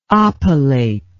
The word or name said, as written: oppilate